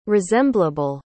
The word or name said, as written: resemblable